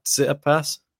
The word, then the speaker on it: Tsitsipas: But Chorich, ever since that Tsitsipas match, did I say it right? Tsitsipas. – Yeah.